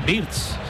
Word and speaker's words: Wirtz: Me encanta cómo lo pronuncias, pero creo que lo pronuncias mal. Porque dices Wirtz, que es Wirtz.